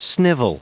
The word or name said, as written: snivel